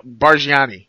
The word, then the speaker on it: Bargnani: Andre Bargnani.